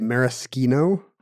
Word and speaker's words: maraschino: In fact, do you know if you look it up in the dictionary, Webster still prefers you say it maraschino? maraschino with cherry. The, with the hard C.